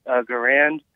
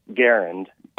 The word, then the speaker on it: Garand: You pronounced it the M1 Garand. John Garand was his name, and it's properly pronounced Garand.